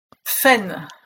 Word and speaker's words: Fayn: Fein.